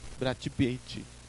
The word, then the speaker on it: Brad Pitt: ¿Sabes cómo se dice Brad Pitt en brasileño? – Brad Pitt. – Brachi Pichi. – Ok. – Bueno, por Brachi Pichi.